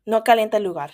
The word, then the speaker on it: no calienta lugar: And that was no calienta calor. Or no, how do you say it? No calienta lugar. Sorry.